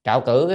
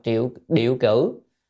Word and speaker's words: trạo cử: Trạo cử có nghĩa là cái chữ này còn gọi đọc là triệu điệu cử. À, nhưng mà thường hay đọc trạo cử hơn.